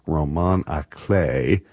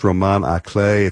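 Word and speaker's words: roman à clef: The name is derived from a genre of books called roman à clay. That's the French pronunciation, spelled R-O-M-A-N space A space C-L-E-F, like the treble clef or bass clef in music. So, but it's pronounced roman à clay.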